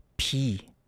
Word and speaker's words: 비: If you translate to the Korean, it's called 비, and that's 비읍 and 이 as the vowel, as you can see on the whiteboard that I'm showing you through our YouTube live stream right now, and you pronounce it as P.